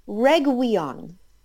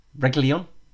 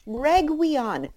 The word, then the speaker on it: Reguilón: And he was pronouncing Reguilón as Reguion. Now, I have a question. Okay? Because this doesn't seem to be, like, a particularly difficult name because it's phonetic. – Reguilón? – Well, okay, no, so I guess it's not phonetic. But there's no W anywhere.